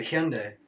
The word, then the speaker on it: Hyundai: That's how you pronounce it, Hyundai.